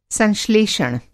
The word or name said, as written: संश्लेषण